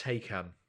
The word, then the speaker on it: Taycan: So T-A-Y-C-A-N is apparently pronounced Taycan. – As it Ty as in fighter, Khan as in Khan!